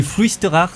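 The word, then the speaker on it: Fluisteraars: Ah, la prononciation, c'est Fluisterars. Du coup, ouais, c'est un groupe de black que j'adore parce que.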